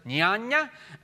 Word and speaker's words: jnana: The technical names are dharma, jnana, it's a really weird way to say it, but that's how you pronounce it, jnana, and bhakti.